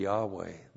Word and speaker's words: Yahweh: You pronounce it be Yahweh.